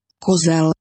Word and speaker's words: Kozel: Kozel.